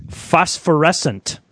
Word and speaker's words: Phosphorescent: Phosphorescent. – Phosphorescent.